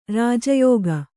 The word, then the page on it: ರಾಜಯೋಗಿ: Kannada